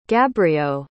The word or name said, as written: gabrio